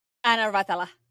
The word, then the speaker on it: Anna Ratala: Anna Ratala.